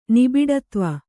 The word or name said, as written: ನಿಬಿಡತ್ವ